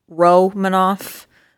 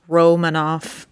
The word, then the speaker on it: Romanov: It's Romanov, according to the official NHL pronunciation guide downloaded from the NHL, it is Romanov.